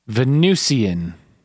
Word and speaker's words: Venusian: I'm pressing the pronunciation button on Google. – Okay. I can't hear it. And this young man says Venusian.